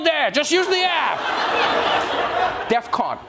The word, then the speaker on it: DEFCON: Which, by the way, I know this is not the point, but that's not how you say it. I know it's not the main issue here. I know that. But it's DEFCON 3. All right? Not DEFCON 3.